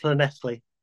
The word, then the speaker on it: Llanelli: It's pronounced Llanelli, if you want the actual Welsh version.